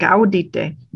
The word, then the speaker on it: audite: See, really, yeah, because that's got a long, well, I think it's got a long I on it. Audite. I have to look at it, but I believe it does.